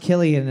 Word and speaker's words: Cillian: Someone said it's pronounced Killian, and I'm not his mom, so I don't know how it's pronounced, to be completely honest.